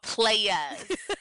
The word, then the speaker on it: Playas: This is called Playas. Like, you have to say it like that. Like, they spelled it like Playas, like Y-A-S.